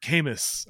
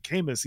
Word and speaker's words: Camus: But like for like probably 2 years of my life, I was really like, I like Albert Camus. I like Camus. He's one of my favorite. You know, I was like that kind of kid. Just like not really, but I was really curious. And so I would listen and eventually I'd be like, oh, okay, I'm an idiot. I was saying that wrong, but I really like his books.